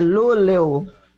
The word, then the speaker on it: Luleå: Será que ela mandou aqui como se fala? Vamos ouvir aqui. Deixa eu ver. – É Luleu. – Luleu. Que você nunca, jamais você Luleu.